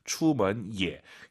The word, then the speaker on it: 出门也: 추먼 예. 예가 올라가면 안 돼요. 예 올라간 순간 틀린 거죠. 뒤에가 일성이니까 반삼성이에요.